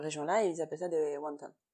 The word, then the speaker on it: wonton: Rondon, en français, on connaît ça plutôt sous le nom de wonton. Wonton. Rondon, c'est la prononciation à la roi, donc mm. euh, dans le chinois euh, standardisé du nord de, la Chine en fait.